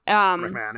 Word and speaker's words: McMahon: But Andrew McMahon, or is that how you say it? – Yeah, McMahon, yeah.